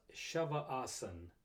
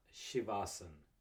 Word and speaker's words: Shavasan: So my ask would be, let's make sure we pronounce the name correctly. It's Shavasan, not Shivasan.